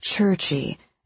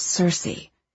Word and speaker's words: Circe: He'd pronounced it churchy, as if Circe had been a modern Italian. "Oh, you dirty autodidact," she said. "It's pronounced Circe."